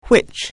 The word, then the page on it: which: anglais